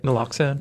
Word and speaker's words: naloxone: You helped create the largest community-based naloxone, is that how you say it? – Naloxone.